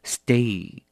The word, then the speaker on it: steak: 最后牛排不要念错了，一定要念成 steak steak。